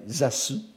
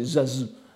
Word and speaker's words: Zasu: Alors il y a aussi une, prononciation très fréquente au Japon de Zasu, c'est Zazu. Mais évidemment, vous voyez qu'en français, le grand Zazu du Tendai ne, sonne pas de la même façon, donc je préfère garder la, prononciation euh, Zasu, le grand Zasu du Tendai.